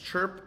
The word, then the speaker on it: Chrp: Chrp, like a bird chirps, and it's spelled without the vowel, C-H-R-P Technologies.